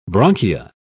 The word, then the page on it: bronchia: British English